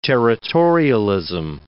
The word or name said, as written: territorialism